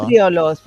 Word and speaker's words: cetriolo: Cetriolo, cetriolo. – Cetriolo. – Sí. – La C i la E es pronuncien Che.